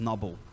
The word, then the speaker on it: Nobel: The Nobel Prize, I think you probably have heard as the Nobel Prize. But the truth is that how you say it, it's Nobel. So just teaching you a little bit of pronunciation there.